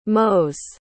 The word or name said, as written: mows